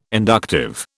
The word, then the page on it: inductive: British English